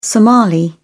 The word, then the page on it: somali: British English